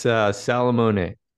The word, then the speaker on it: Salomone: So how do you pronounce your last name? – It's Salomone.